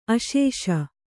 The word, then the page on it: ಆಸಿಸು: āsisu